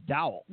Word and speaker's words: Dowell: I believe it's Dowell. – Yeah, Doolittle. He has a Dowell is spelled D-O-W-E-L-L, right? Do well.